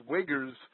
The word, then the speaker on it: Uyghurs: And there's a people called, I like to call them the Uyghurs, right? And okay. – that's abusing the pronunciation of the word. – Sure. – It's Uyghurs or something similar to that.